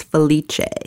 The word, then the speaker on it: Felice: And in Italian, it's pronounced Felice. – Felice.